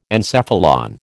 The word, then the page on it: encephalon: American English